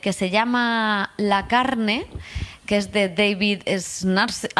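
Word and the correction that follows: Szalai: David Salai.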